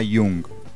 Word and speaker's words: Jung: Este se escribe Jung, pero se pronuncia Jung.